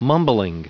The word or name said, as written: mumbling